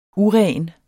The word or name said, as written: uren